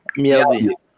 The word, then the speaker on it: Mjällby: I'm going to pronounce it wrong, but MJALLBY, Jalby? – Mjällby.